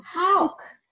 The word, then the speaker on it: Hawk: Hi everyone, this is your nurse advocate, here today on our Health Chats Among Friends with a great friend, Leslie Hawk. Did I say that wrong? Hawk. Did I say that right? – Hawk. It's like how with a K on the end, huh? – Hawk.